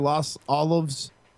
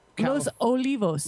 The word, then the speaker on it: Los Olivos: Investigator from the sheriff's office told the team searching the 2,700-acre Los Olivos. – Los Olivos. – Los Olivos. – You're Hispanic. Say it right.